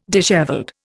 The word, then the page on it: dishevelled: Indian